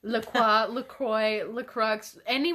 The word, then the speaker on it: LaCroix: I will not correct you if you say LaCroix, but if you come for me and you say, why are you saying LaCroix? That's wrong. LaCroix. I'm like, that's how you say it. It's a French word.